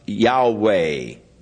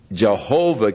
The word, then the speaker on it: Yahweh: So, the general consensus of opinion is that the name was to be pronounced Yahweh. But somewhere along the line, the pronunciation of Jehovah came along, or Yehovah, and it has become more popular, but Yahweh is probably the correct pronunciation, though we do not know for sure.